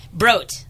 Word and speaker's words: Brot: Brot. So Brot. I always like to tell everybody, Steve, I'm going to get you a shirt. It says You can have a brot at Brot. It's a long O.